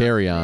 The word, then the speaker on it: Kerryon: And there's a man, Kenyon Johnson. Kayon Johnson, I may have pronounced Kerryon.